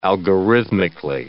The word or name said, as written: algorithmically